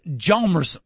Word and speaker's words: Hjalmarsson: By the way, I finally did see the hit on Nicholas Hjalmarsson, the man with the silent H.